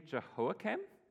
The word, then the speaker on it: Jehoiakim: And we have King, and I'm going to butcher his name, Jehoiakim. Okay, I'm seeing a nod, so I said it right this time.